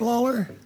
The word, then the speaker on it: Lawlar: Sure, Rick Lawler. – All right. – L-A-W-L-A-R.